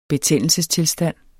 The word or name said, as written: betændelsestilstand